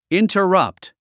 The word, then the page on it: interrupt: ìntərʌ’pt; インタラプト